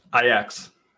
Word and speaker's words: Ajax: Ajax.